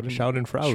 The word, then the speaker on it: Schadenfreude: Schadenfreude.